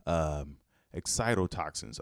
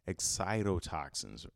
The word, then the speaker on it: excitotoxins: Excitotoxins. I'm sorry. I'm mispronouncing everything this morning. Excitotoxins.